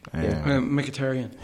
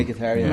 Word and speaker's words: Mkhitaryan: Mkhitaryan. That's how he pronounced it. Yeah, and he said it. Mm. He's like, this is how you pronounce my name for the fans.